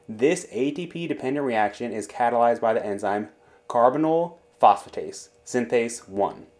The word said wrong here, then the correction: carbamoyl phosphate synthetase 1: God, I fucked up that name. How do you pronounce that? Carbamoyl phosphate synthetase 1.